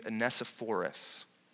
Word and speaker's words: Onesiphorus: The positive example found in verses 16 through 18 is in a man named Onesiphorus. And if you say it wrongly, you'll sound like you're talking about a dinosaur.